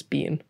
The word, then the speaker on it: Bien: It's spelt B-I-E-N, but I think it's Bean.